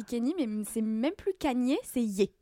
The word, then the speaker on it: Kanye: Eh bien, vous l'avez entendu dans ma bouche depuis le début de l'épisode, c'est Kanye, c'est pas Kenny, pas Kanye. Kanye.